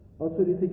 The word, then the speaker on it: اتوریته: انگلیسیش اتوریتی، یعنی ترجمه تلفظ درستش اتوریته، که تو فارسی اومده به معنی اقتدار، به معنی تسلطه، به معنی مرجعیته.